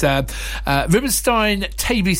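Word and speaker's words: Rubinstein-Taybi: And it's Rubenstein-Taibbi syndrome. Have I said that right? – Yes, yeah.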